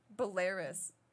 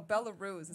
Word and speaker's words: Belarus: How do you pronounce it? – Belarus. Oh, Belarus is how it's supposed to say.